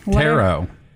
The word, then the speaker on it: tarot: I've never had a tarot. How do you say it? – Tarot. – No, the T is, there's a T at the end of it. – It's silent.